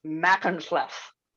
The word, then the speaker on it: Machynlleth: As far as I can tell, it is Machynlleth. I watched some YouTube videos of locals pronouncing it.